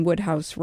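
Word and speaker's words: Wodehouse: And we're pronouncing Woodhouse right.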